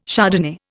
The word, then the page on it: Chardonnay: Indian